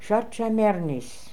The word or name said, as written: Shat Chai Mernis